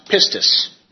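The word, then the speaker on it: pistis (πίστις): And faith is the Strong's word G4102, pronounced pistis.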